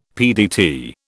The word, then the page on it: PDT: British English